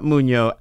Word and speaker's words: Mugno: How does he pronounce his last name? – M-U-G-N-O. – We'll just say Mugno. I'll do it as Italian.